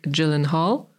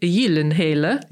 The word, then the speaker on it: Gyllenhaal: Powstał na razie jeden sezon, ma powstać drugi i w roli głównej występuje pan Jake Gyllenhaal. Tak się wymawia jego nazwisko po angielsku, a oryginalnie w języku skandynawskim Jake Gyllenhaal, który ma również znaną siostrę aktorkę Maggie Gyllenhaal lub Maggie Gyllenhaal.